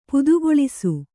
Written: ಪದಗೊಳಿಸು

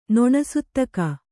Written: ನೊಣಸುತ್ತಕ

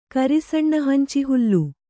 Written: ಕರಿಸಣ್ಣಹಂಚಿಹುಲ್ಲು

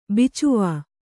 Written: ಬಿಚ್ವಾ

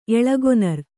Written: ಎಳಗೊನರ್

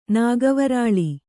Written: ನಾಗವರಾಳಿ

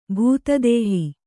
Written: ಭೂತದೇಹಿ

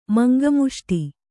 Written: ಮಂಗಮುಷ್ಟಿ

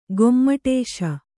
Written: ಗುಮ್ಮಟೇಶ

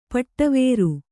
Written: ಪಡಿವರ್

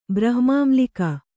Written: ಬ್ರಹ್ಮಾಮ್ಲಿಕ

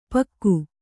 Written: ಪೀಕು

